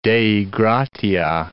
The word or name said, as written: Dei gratia